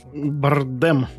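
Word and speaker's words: Bardem: чем мне понравился, поправь, если я неправильно назову его имя, Хавьер Бандер, Бандер, Бардем.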